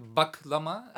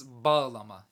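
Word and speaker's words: bağlama: But is it, it's a soft G, yeah? So soft you wouldn't G, say Baglama, you would say Baglama. – Yeah, it's okay. Not Baglama, okay. It is Baglama. It's a H. It's a softened G.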